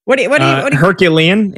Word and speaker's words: Herculean: So, like, Fernando Mendoza would have to do, like, be Hercules, Heroclean. How do you say that word? – Herculean. It would take a Herculean season.